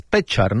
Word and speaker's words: Pečar: En el caso de Martín es Pečar, ¿no? – Martín Pečar, sí, la tilde que no existe es que en la E.